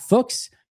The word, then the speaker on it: Fuchs: It looks like a swear word, but Scott Fuchs, as the Packers said, rhymes with books.